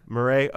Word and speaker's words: Meera: And right, but is that how you say her name? M-E-E-R-A? – Marie or Marae. – Marae. Okay, we'll call it Marae.